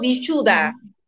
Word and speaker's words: Vishuddha: y se pronuncia en sánscrito Vishuddha, y significa entre dos mundos.